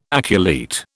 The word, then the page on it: aculeate: British English